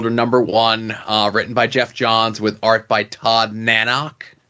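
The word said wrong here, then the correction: Nauck: Is that how you pronounce it? – I believe it's just Todd Nock. – Todd Nock, okay.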